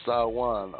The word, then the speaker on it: Samhain: The festival of the dead called Perentula is more typically linked to the Celtic festival of Samhain, whose origin spelling Samhain, pronounced Sawan or Samhain.